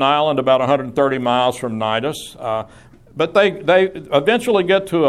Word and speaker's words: Cnidus: The ship did eventually arrive at Cnidus. Seems funny to pronounce something Cnidus that begins with a C, but that's apparently what's available to us here in terms of pronunciation.